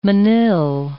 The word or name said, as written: manille